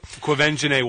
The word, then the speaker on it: Quivenzhané: Quivenzhané Wallace, yes.